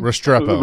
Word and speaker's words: Restrepo: Restrepo.